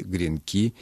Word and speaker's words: гренки: Галина, правильным остается то, что вы читаете в орфографическом словаре. Тефтели, гренки и жалюзи.